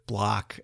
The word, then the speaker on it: Bloch: His pedigree, I guess, is Robert Block, or how would you pronounce that? B-L-O-C-H. – I would say Block.